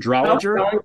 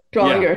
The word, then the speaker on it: Drollinger: How does he say his name? Drollinger. – Drollinger.